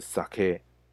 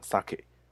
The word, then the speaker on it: 酒: 那这边附带一提，清酒的日文是 sake。好，大大家常常有时候会讲 sake，其实是不对的，因为它其实虽然发音很像，但是因为重音的不同，如果你讲 sake 的话，其实指的是鲑鱼，好，是鲑鱼哦。好，所以如果要讲酒，记得重音要放在后面，叫 sake。